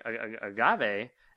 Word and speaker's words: agave: Because this recipe sounded very good to me, but it mentions using agave. I'm probably even pronouncing that wrong. – No, that's pronounced correctly.